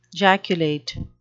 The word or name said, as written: jaculate